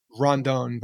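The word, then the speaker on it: Rodón: There's no N, and so many people say it like this, there's no N in the middle. – Yeah, I know that. Rodon.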